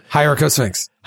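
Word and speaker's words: Hieracosphinx: And it basically, like, one of the first things they come in contact with are these guardians, and they're Hierosphinxes. Is that how you pronounce it? – Hierarchosphinx. – Hierarchosphinx.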